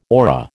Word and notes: aura: American English

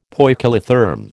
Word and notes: poikilotherm: American English

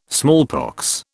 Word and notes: smallpox: British English

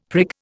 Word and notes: prig: Indian

